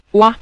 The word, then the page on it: wa: wa5